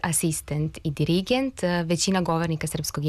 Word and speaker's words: asistent: A ti akcenti poput argument i asistent nisu nikakvi novi akcenti, već su akcenti koji su doneti još pre dva veka u vreme Vuka Karadžića i Đure Daničića, kada je propisano da silazni akcenti mogu stajati samo na prvom slogu višesložnih reči i na jednosložnim rečima.